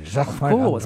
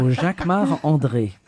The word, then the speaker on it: Jacquemart-André: You say it like André. – A Frenchman. – Jacquemart-André. Because I'm French. So Jacquemart-André.